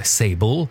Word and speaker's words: Sable: Sable, ou prononcé en anglais Sable, c'est un jeu qui est disponible évidemment sur sur Steam, sur Epic Game Store.